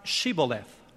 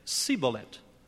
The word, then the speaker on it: Shibboleth: If he said nay, then said they unto him, "Say now Shibboleth." And he said, "Sibbolet," for he could not frame to pronounce it right.